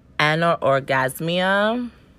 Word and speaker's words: anorgasmia: The lack of orgasm, the clinical term for that is, and I'm about to butcher this word, and I'm so, so sorry, it is anorgasmia. I actually said that right.